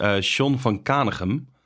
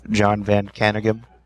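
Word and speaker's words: Jon Van Caneghem: it's an American, but he has a Dutch name, so I can pronounce it in the Dutch way, which is Sean van Kanegem. But I don't think he goes by that name, because he's actually in America, American-born, so he's probably John van Kanegem, something like that.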